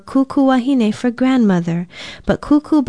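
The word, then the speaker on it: kūkū: We hear the word tutu used so often, but many of us may not know that kuku is another, perhaps more traditional pronunciation for today's Hawaiian word. It is spelled K-U-K-U with macrons over both vowels.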